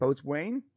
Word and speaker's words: boatswain: So the first scene, this he says about the boatswain, you know, the boatswain. How do you say it? Boatswain? – Boatswain. – About the boatswain. – Looks like Wayne, whistle.